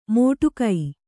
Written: ಮೋಟುಕೈ

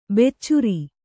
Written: ಬೆಚ್ಚರ

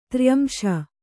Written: ತ್ರ್ಯಂಶ

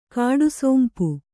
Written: ಕಡುಸೊಂಪು

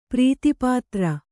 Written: ಪ್ರೀತಿಪಾತ್ರ

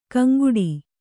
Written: ಕಾಂಗಡಿ

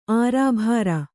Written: ಅರೆಬರ್

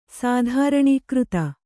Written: ತೃಣೀಕೃತ

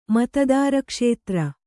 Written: ಮತದಾರಕ್ಷೇತ್ರ

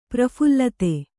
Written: ಪ್ರಫುಲ್ಲತೆ

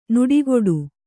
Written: ನುಡಿಗೊಡು

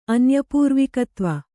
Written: ಅನ್ಯಪೂರ್ವಿಕತ್ವ

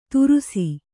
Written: ತುರುಸಿ

